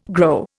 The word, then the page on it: grow: Indian